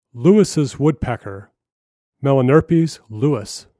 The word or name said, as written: Lewis's Woodpecker